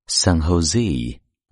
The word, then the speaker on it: San Jose: 比如说，美国加州的西部城市圣何塞，这个城市的名字用英文的发音叫 San Jose。字母 J 发 h 的音，所以你现在知道了。